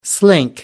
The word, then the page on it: slink: American English